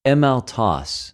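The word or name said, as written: ἐμαυτου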